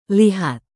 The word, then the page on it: lihat: indonesian